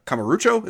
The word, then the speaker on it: Kamurocho: Kamurocho. Is that how you say it? – Close enough.